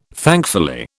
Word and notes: thankfully: British English

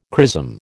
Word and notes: chrism: American English

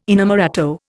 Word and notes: inamorato: Indian

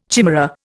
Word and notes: chimera: Indian

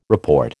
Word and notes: report: American English

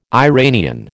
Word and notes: Iranian: American English

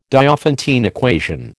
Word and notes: Diophantine equation: American English

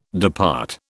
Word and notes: depart: British English

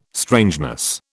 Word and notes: strangeness: British English

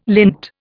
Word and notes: lint: Indian

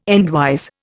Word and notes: endwise: Indian

